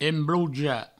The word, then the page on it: embloujar: emblouja